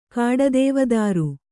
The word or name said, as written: ಕಾಡದೇವದಾರು